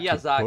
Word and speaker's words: Miyazaki: Miyazaki? – Miyazaki, é Miyazaki.